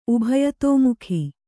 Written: ಉಭಯತೋಮುಖಿ

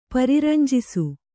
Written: ಪರಿರಂಜಿಸು

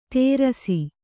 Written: ತೇರಸಿ

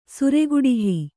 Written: ಸುರೆಗುಡಿಹಿ

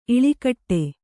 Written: ಇಳಿಗಡೆ

